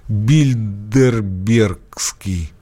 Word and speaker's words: Билдербергский: Да-да-да, всемирное правительство, Билдербергский, да, правильно сказал? – Да. – Клуб? – Кажется, так.